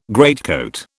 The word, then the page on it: greatcoat: British English